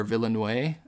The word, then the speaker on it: Villeneuve: So number four, I have Arrival, which was the sci-fi feature directed by Denis Villeneuve or Villeneuve. – Nice pronunciation. – Thank there. – You. – Nailed it.